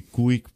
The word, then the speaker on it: Cuijk: Zurich spricht man das, glaube ich, aus, oder auch Zürich. – Kuik, keine Ahnung, C-U-I-J-K.